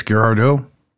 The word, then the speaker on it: Girardeau: The Cape, how do you pronounce that? How would you guys pronounce that? – Girardeau. Sounds French. – Yeah, definitely. Yeah, I think it's Girardeau. Girardeau.